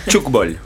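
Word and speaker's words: Tchoukball: Recibimos en Mundialas 2.0 para hablar de Showball. Bienvenidos. ¿Lo dije bien? – Hola, ¿qué tal? – ¿Cómo estás? ¿Cómo se pronuncia? – Chukball. – Chukball. – Exacto.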